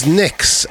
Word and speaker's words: Nyx: and I actually spoke to him on the phone a couple of days afterwards, and it is Nyx, which is a Greek god.